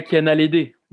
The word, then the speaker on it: Kiana Ledé: en euh, a le D, je sais pas si je le dis bien, Kiana Ledé. – Ouais, Kiana Ledé.